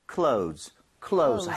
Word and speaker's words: clothes: 자, clothes 이게 옷이라는 뜻인데 발음 좀 주의하셔야 되는 게 clothes가 원래는 맞아요. – 아, 어려워요. – 그래서 원어민들은 정말 그 뭐라 그럴까 영상을 슬로우하게 돌려보면 하는 게 보이긴 할 겁니다. – 진짜요? – Clothes. 그렇지만 이게 워낙 혀도 귀찮아하는 발음이에요. – 그죠. – 그래서 Z로 발음하셔도 문제가 없습니다. – 아. – So, close 해도 소통하는데 전혀 문제가 없으니까요.